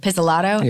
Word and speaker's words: Pizzolatto: And I had to audition in Cary Fukunaga's trailer with Nick, how do you say his last name? – Pizzolatto. – Pizzolatto.